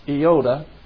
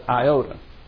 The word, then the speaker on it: iota: Actually, it's pronounced iota, but when it comes into English, people call it iota.